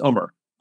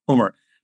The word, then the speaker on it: Umar: okay, no, it's Umar, it's Umar, it's Umar, Umar. Now I, I understand people can't pronounce it properly, which is fine. I appreciate the effort, but hmm. they don't have to say it the right way.